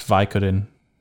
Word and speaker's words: Vicodin: And I said Vidican, and it's Vicodin.